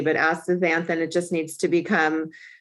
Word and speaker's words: astaxanthin: Okay, I'm going to get the astaxanthin. Did I pronounce it correctly? – Astaxanthin, yeah.